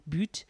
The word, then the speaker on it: BUT: Anyway, so this place was publicising that they're, it's called Boot for anyone who is in France and knows that B-U-T, but it's called Boot. Pronounced Boot,